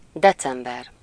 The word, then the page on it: joulukuu: /ˈjou.luˌkuː/